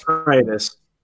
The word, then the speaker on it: Freitas: I assume I've said that name wrong. Is it Freitas? Freitas? – It's Freitas. – Oh, Freitas. Nailed it.